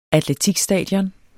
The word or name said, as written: atletikstadion